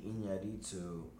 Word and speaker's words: Iñárritu: am uh, gonna slightly butcher his last name, Iñárritu Alejandro G. Iñárritu. Uh, that was a good pronunciation, Mexican I think.